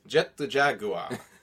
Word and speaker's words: Jet Jaguar: Or as he's known in Japanese, Jet the Jaguar. That's actually how they pronounce it.